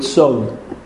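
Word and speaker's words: Tzon (צאן): We don't pronounce the Aleph, or the word Tzon, sheep, is spelled in the Tanakh, Tzadi Aleph Nun. But the Aleph doesn't have much of a position.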